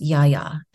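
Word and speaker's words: Yaya (Γαία): It's actually pronounced Yaya in Greek.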